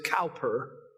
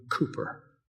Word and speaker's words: Cowper: The author of our hymn this morning, if you look at his name, you might want to pronounce it Cowper, but his name was actually William Cooper.